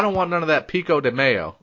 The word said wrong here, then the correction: pico de gallo: It's pico de gallo, you stupid fucking bitch.